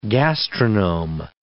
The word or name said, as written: gastronome